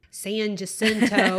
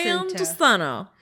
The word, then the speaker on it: San Jacinto: San Jacinto. – That's how they say it in Houston. San Jacinto.